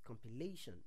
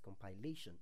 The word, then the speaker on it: compilation: But the correct pronunciation for that word is compilation, not compilation.